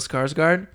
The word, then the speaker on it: Skarsgård: I feel like Bill Skarsgård.